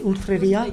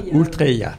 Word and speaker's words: Ultreia: C'est comme ça qu'il faut le prononcer, Ultreria. – Ultreia.